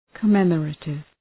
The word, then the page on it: commemorative: kə’memə,reıtıv